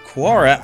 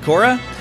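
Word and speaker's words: Quorra: also, the Tron characters Sam Flynn and Quora... I forgot how to pronounce that name, but I think Quora is correct, right? Quora? Yeah, Sam Flynn and Quora are going to be available as physical figures for the release of 3.0, which is cool.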